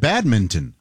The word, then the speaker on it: badminton: You don't pronounce that N? – You do not. – No, badminton. – Badminton. – I didn't realize you said badminton.